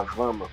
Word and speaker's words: Vama: Mas acho que é Vama, V-A-M-A, Vama.